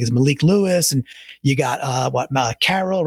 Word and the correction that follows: Corral: Matt Corral, I think Corral, is how you say yeah.